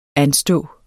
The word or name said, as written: anstå